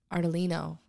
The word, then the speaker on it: Ardolino: Ardolino, right.